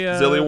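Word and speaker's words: Xillia: You got the Zillia, am I pronouncing that correctly? – Zillia 1 and 2.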